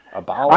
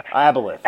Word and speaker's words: Aboleth: Aboleth. I'm going to say Aboleth. – Aboleth.